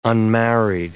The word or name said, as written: unmarried